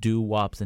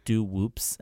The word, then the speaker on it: Doo-Wops: but Doo-Wops and Hooligans, which, funny enough, I'm going to out myself a little bit here. But I originally pronounced it as Doo-Whoops and Hooligans.